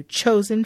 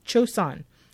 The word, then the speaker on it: Choson: She clips in her long extensions and she heads to Chosen Family Animal Shelter. Chosun, not chosen. – It's a play on words. – It's spelled C-H-O-S-O-N.